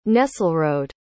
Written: Nesselrode

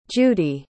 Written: judi

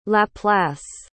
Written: laplace

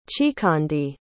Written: chikondi

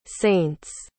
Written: saints

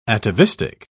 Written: atavistic

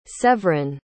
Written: sevrin

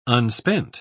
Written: unspent

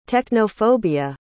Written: technophobia

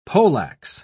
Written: poleax

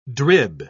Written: drib

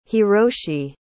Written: hiroshi

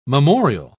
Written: memorial

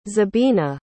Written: zebina

